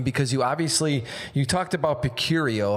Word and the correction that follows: Pecunio: I mean, it's actually pronounced pecunio. Pecun means money in Latin, and IO is initial offer, pecunio.